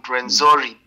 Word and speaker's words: Rwenzori: Yes, the moon, the mountains of the moon, Rwenzori, beautiful mountains, beautiful.